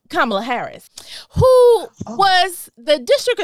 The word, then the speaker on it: Kamala: Kamala, make sure you say her name right, Kamala Devi Harris is going to be the vice president of the United States.